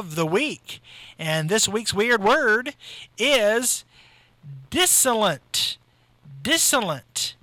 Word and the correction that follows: dissilient: You know what? I pronounced that wrong. It's dissilient. – Disilient. Disilient. Not dissonant. Disilient.